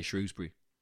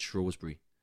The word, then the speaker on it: Shrewsbury: But there's half of the crowd in Shrewsbury get really angry when you say Shrewsbury because half of them pronounce it Shrewsbury.